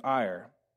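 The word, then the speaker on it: Er: Now it looks like Ur, but it's pronounced like the front half of Ireland. His name is Ir.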